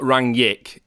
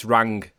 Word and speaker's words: Rangnick: So we're being told it's not Rangnick, it's Rangnick.